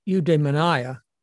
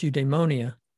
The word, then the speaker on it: eudaimonia: And Aristotle defined eudaimonia, often pronounced eudaimonia, as meaning a good daemon, a good soul, a good spirit, meaning happiness.